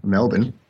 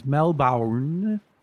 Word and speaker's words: Melbourne: That is correct. And that was a good pronunciation, Melbourne. – Well, then I'll correct myself. I apologize. Melbourne, Australia. That's correct, right? – Yeah, that's more typical, yes.